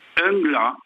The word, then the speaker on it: Unglas: Angla. – Angla, à la française, et on l'écrit U-N-G-L-A-S.